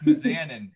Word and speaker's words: Mizanin: It's Mizanin or something, isn't it? – Mizanian. – I say it wrong every time. I don't know what it is. – All right. – What's his last name? Anybody know? – No, I have no idea. – No, it's Mizanin.